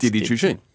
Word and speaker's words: Didi Chuxing: Didi Chuxing. – Didi Chuxing.